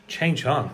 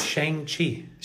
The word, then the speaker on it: Shang-Chi: Chang Chung. – I don't know what his name is. – Shang-Chi.